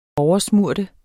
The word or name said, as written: oversmøre